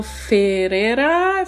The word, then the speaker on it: Ferrera: Today we have with us Arno Ferrera, if I pronounce it right.